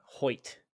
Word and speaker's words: Hoecht: Is that how you say it? Hoecht? – Hoecht. Yeah. H-O-E-C-H-T.